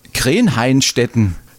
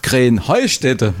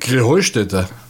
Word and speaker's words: Krähenheustätte: Ja, ihr saget immer Krähenhainstetten. Und dabei heißt es doch Krähenheustätte. Oder wie ist es? – Krähenheustätte. – Ja, genau. Und das die Betonung auf dem Heu.